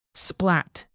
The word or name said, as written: splat